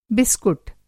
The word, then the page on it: बिस्कुट: biscut; بسکٹ